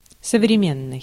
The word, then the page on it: современный: /səvrʲɪˈmʲenːɨj/